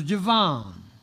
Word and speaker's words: Javan: I just know that in Hebrew, you pronounce A as you would the A in Father. It's not a long A. So Javon, Elisha, Tarsus, Gidim, and embolden them.